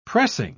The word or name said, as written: pressing